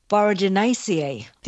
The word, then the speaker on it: Boraginaceae: Yes, and it's a herb in the flowering plant family, Boraginaceae. Did I say that right? – You did. You said that right.